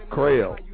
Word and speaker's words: Crell: C-R-E-L-L. – There you go. – How do you say it? – Krell. – Krell. – Language here, goddammit. Krell. Like rail, put a C on it.